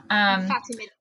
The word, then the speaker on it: Fatimid: Fatimid, that's correct.